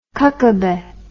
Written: kokobeh

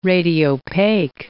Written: radiopaque

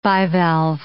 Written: bivalve

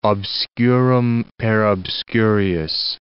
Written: obscurum per obscurius

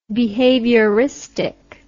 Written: behavioristically

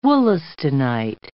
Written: wollastonite

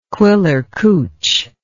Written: quillercouch

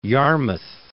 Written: Yarmouth

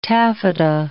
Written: taffeta

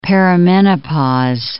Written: perimenopause

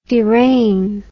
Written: deraign

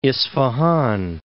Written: Isfahan

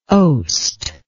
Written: oost

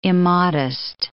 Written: immodest

